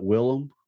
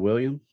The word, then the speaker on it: Willem: And you're actually supposed to pronounce it Willem instead of William.